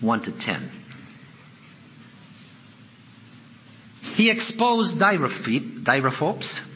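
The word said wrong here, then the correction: Diotrephes: Diotrophes, that's it.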